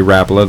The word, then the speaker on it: Rapala: I get that question every trip, and the best way to answer it is Lori Rapala, the founder of our company. That's his last name, and that's how he pronounced it. And I think it reads Rapala, but Lori Rapala and then the entire family, that's the history of their family tradition.